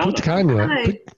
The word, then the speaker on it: Punta Cana: Punta Cana.